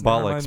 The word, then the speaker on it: Bollocks: It's one of the lesser known songs from the Nevermind the Bullocks album. All right, look, I probably mispronounced Bollocks. – Bollocks, the probably. Bollocks.